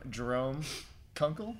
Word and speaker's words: Jerome Kunkel: Welcome, Jerome Kunkel.